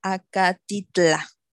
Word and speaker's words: Acatitla: Lo que nos impactó fue el, que se la llevaran a este Santa Marta Acatlit, que así lo pronuncié bien, ¿verdad? Santa Marta Acatitla.